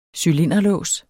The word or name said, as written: cylinderlås